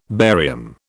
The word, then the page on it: barium: British English